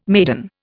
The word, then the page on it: maiden: Indian